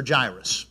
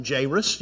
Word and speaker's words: Jairus: Yeah, I found out this week that there are multiple pronunciations for Jairus. You can call him Jairus.